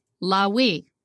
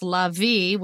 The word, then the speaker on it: Levi: Therefore was his name called Lawi." That's how you say it in ancient Hebrew. Of course, in modern Hebrew, it's Lavi with a V sound. And in English, we say Levi.